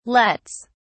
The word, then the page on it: lets: British English